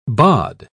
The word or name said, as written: bod